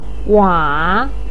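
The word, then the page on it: wa: tone 3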